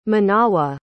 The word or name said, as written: manawa